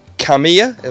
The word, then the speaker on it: Kamiya: Kamiya, am I saying that right? – Yes.